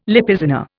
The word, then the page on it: Lipizzaner: Indian